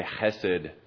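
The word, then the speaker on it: hesed (חסד): The word that I asked you to learn with this book is the word hesed. And my daughters will pronounce that with their throats and they'll say hesed.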